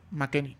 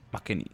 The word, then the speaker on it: McKennie: Weston llegó ¿Cómo se pronuncia? McKennie. McKennie.